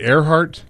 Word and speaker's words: Erhardt: Is it Earhart? – Yes.